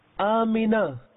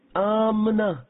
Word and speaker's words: Amina: Amina. Meem has kasra under it. Amina. Normally, we pronounce meem consonant. Generally, Amna is pronounced. So, correct pronunciation is Amina. This is Arabic.